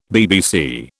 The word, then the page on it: BBC: British English